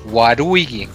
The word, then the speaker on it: Waluigi: Eh, es por eso también que Waluigi, cuando lo pronuncias también en japonés, suena como Waruigi.